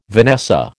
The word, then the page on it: vanessa: American English